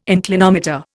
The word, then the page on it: inclinometer: Indian